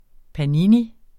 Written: panini

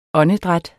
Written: åndedræt

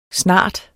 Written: snart

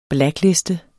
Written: blackliste

